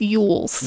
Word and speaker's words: Elwes: Produced by Greg Hoffman, Oren Coles, Mark Berg, starring, oh, here's another one I don't know how to say, Carrie Elwes? – I think it's Yules. – Yules? – I think it's Yules.